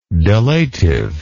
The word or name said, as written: delative